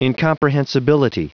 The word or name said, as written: incomprehensibility